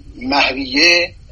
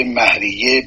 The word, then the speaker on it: مهریه: اما مهریه، من این رو اصلاح بکنم، مهر به کار برده نمیشه، تلفظ مهر درست نیست، مهر و مهریه. همه اساتید ما و قضات دادگاه و حقوقدان ها این کلمه را به صورت مهریه بر وزن شهریه تلفظ می کنم.